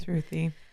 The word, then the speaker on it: Sruthi: And so this is when the journalist over at Reply All, Sruthi, I can't say her name. – Sruthi.